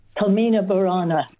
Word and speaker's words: Carmina Burana: How did I go with the pronunciation? – Carmina Burana. – Burana. Yeah. I had trouble getting the Burana out.